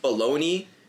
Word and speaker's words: Bologna: Like, I said this earlier, like, baloney. Like, why is it spelled like Bologna? don't you say it.